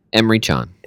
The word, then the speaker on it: Emre Can: How do you say his name? – Emre Can.